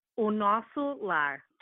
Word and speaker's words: Nosso Lar: And the book that it's based on is called, it's spelled N-O-S-S-O-L-A-R, and it translates to our home. So how do you pronounce that? – O Nosso Lar.